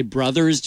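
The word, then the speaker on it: Warner Bros.: We say brothers, but we spell it bros. It is never spelled, the word is never spelled out. That is actually incorrect. So it's always spelled bros, but we say brothers.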